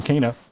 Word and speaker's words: Kena: I keep saying it wrong. Kena. And it was super cool.